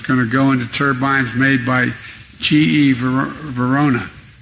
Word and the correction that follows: Vernova: Excuse me. I mispronounced it. Vernova in Florida, New York.